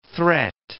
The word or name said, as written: threat